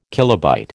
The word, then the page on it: kilobyte: American English